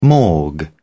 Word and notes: morgue: British English

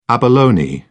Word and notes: abalone: British English

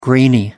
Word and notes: grainy: American English

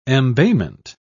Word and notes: embayment: British English

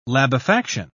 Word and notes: labefaction: British English

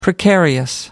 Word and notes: precarious: American English

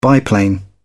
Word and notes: biplane: British English

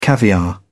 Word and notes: caviar: British English

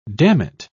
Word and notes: dammit: British English

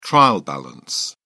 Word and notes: trial balance: British English

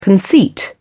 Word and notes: conceit: British English

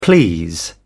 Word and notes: please: British English

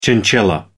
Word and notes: chinchilla: American English

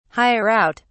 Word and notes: hire out: British English

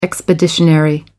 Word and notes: expeditionary: American English

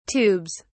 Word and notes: tubes: British English

